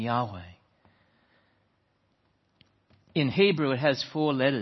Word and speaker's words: Yahweh: And we pronounce it Yahweh.